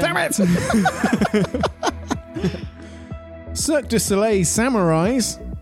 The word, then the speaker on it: Soleil: How do you say Soleil? – Soleil. – Soleil. – Cirque du Soleil. No, yeah. L's. Forget the L's. – Forget the L's. – Except the one in the middle.